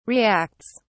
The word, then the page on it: reacts: British English